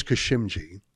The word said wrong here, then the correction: Karimji: if I got that right. – Karimji. – Thank you very much. Karimji. Thank you for correcting me.